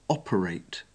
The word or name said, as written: operate